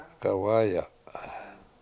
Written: k7awA7ya7

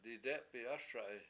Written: didE7pi7ASa7i

